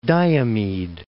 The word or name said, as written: Diomede Islands